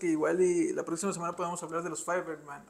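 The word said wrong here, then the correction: Fabelman: o cómo se pronuncia. Los Fableman.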